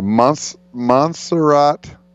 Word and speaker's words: Montserrat: It's taking on, man, I don't even, how do you pronounce this? MNTSERRAT. Montserrat? That's, it's very offensive to talk about someone whose name you can't pronounce. – Why? Wheel of Fortune makes it's an epic show based on spelling it out letter by letter. – And you do have to say it properly. – Okay, Montserrat Conejo.